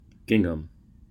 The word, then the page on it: gingham: American English